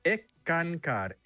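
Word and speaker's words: Eckankar: Se pronuncia Ekankar, Sekankar, ECANCAR. – significa colaborador con Dios.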